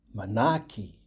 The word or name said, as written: Manaaki